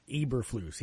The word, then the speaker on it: Eberflus: Let me know it's Matt Eberflus. I think I said that right. Yeah, Eberflus.